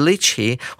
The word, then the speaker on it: litchi: lychee 它有两种拼法，第一种拼法叫做 l y c h e e，我们会念成 lychee；另外一种拼法是 litchi，l i t c h i，可以念成 litchi 或者是 lychee，两种念法都是可以的。